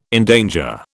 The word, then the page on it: endanger: British English